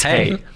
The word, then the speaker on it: hej: It's hey, as we say in Swedish. – H-E-J. – Yeah, here we go. – One of my favourite things when you go to Sweden is people going, hey, hey.